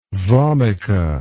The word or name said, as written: vomica